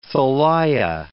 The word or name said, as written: Thalia